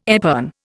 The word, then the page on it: epergne: Indian